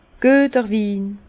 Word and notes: Güeter Win: Bas-Rhin; Herrlisheim

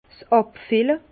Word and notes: 's Àbfille: Bas Rhin; Herrlisheim

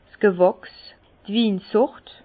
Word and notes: 's Gewachs, d' Winsort: Bas-Rhin; Herrlisheim

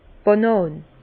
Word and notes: d' Bànàn: Bas Rhin; Herrlisheim

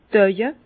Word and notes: d' Auwe: Bas-Rhin; Herrlisheim

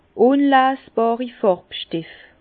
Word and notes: ùnleesbàri Fàrbstef: Bas Rhin; Herrlisheim

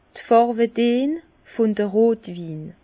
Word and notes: d' Fàrwedeen vun de Rotwin: Bas-Rhin; Herrlisheim

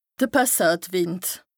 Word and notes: de Passatwìnd: Bas-Rhin; Strasbourg

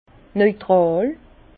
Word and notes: neutràl: Bas Rhin; Herrlisheim

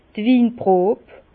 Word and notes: d' Winprob: Bas Rhin; Herrlisheim

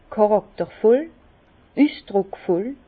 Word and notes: charaktervoll, üsdrùckvoll: Bas-Rhin; Herrlisheim

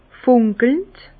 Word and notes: fùnkelnd: Bas-Rhin; Herrlisheim

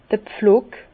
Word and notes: d'r Pflock: Bas-Rhin; Herrlisheim

